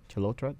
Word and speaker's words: Chalotra: It stars Anya Chalotra. Chalotra, that's how it's pronounced.